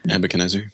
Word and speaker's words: Nebuchadnezzar: Nebuchadnezzar.